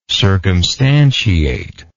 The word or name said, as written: circumstantiation